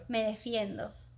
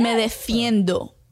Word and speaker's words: me defiendo: Me defiendo. – Me defiendo. Is that what you said? Did I say it right? – Yeah. Me defiendo.